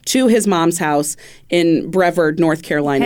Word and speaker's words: Brevard: Brevard, North Carolina. – Brevard? – You ever heard of this? – Spell it. – B-R-E-V-A-R-D.